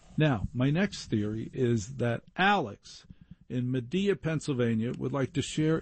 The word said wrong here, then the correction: Media: I heard somebody else mispronounce media recently. They said on some other station, Medea.